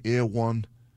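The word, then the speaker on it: Erewhon: I went into Ear One. You know Ear One, the health food place. Am I saying it wrong? Ear One.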